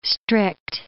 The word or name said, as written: strict